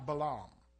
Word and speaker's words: Balaam: It's pronounced Balaam in the Hebrew.